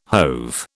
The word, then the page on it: hove: British English